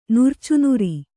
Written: ನುರ್ಚುನುರಿ

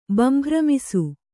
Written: ಬಂಭ್ರಮಿಸು

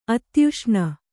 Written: ಅಧ್ಯೇಷಣೆ

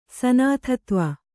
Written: ಸನಾಥತ್ವ

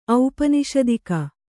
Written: ಔಪನಿಷದಿಕ

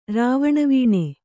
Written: ರಾವಣವೀಣೆ

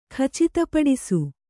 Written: ಖಚಿತಪಡಿಸು

